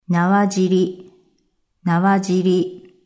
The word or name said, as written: Nawa jiri